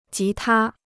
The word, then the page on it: 吉他: jí tā